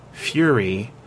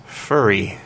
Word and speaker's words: fury: But one of the things that often happens is I notice when students are reading it, they mispronounce the word fury as furry.